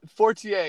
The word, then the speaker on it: Fortier: Fortier, yeah.